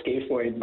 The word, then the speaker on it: scaphoid: We call it the scaphoid, but really it's spelled S-C-A-P-H. So people will say scaphoid, but most commonly we call it the scaphoid bone.